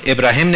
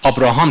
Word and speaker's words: ابراهیم: نسخه های حجازی، نسخه های شامی همشون ابراهیم نوشته شده چون اونجا اونا بدون الف و بدون دندونه یا، چون اونا ابراهام تلفظ میکردن. تو قرائت ابن عامر هم همین امروزه توی شمال آفریقا که میخونن ابراهیم رو ابراهام میخونن، تو قرائت یکی از قرائت صفحه ناشی از منطقه شام هست که خیلی به منطقه مسیحی نشین نزدیک بوده.